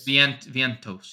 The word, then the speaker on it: Vientos: Okay, and then Ventios is min price again. – Yep, I'm only because of salary, not because of the matchup. – Is it, how do you say his name? Ventios? Vientos? – Vientos.